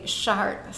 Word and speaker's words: Chartes: And it is, may I interrupt, because I know that some Americans thought it was the École des Chartes, meaning the cathedral, but it's actually Chartes, spelled like charts with an E-S at the end, right? – Yes, exactly.